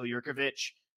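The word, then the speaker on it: Jurkovec: I think Boston College has a higher upside this year with, who is it, Phil Yurkovich. Did I say it right? – Yeah, pretty good.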